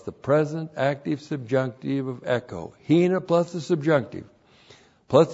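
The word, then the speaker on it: echo (ἔχω): Hina plus the subjunctive, H-I-N-A. Here it introduces a result clause. And then we have the verb echo. E-C-H long O, echo.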